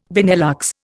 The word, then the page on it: Benelux: Indian